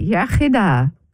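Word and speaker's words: iechyd da: You say yachida, good health. – Yachida. – Yeah, the English say yachida, but it's yachida, good health.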